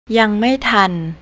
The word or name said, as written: youngmaitan